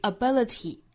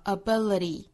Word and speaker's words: ability: 美音之中是 ability，其实最大的区别哈、啊，就是在最后一个音节字母 t 的发音，美音呢是发成一个弹舌音的的，英音之中是爆破感十足的 t 的发音，所以 ability 和 ability，那再变复数呢，就是 abilities。